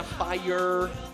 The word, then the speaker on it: fire: é a pronúncia americana fire, fire, fire, fire. O inglês britânico seria um fire, né, fire. Esse R deles não é um R puxado, ele é um R.